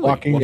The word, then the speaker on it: Joaquin: Jacqueline. – Oh, really? Joaquin yeah.